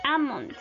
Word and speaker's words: almond: Silent L, la L silenciosa. Almond, almond, almond. Almendra.